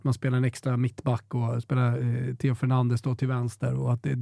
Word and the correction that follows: Hernandez: Jag brukar säga Hernandez, ja, för att man uttalar ju inte H-et. – Nej. Du uttalade H-et så lite att det blev ett F.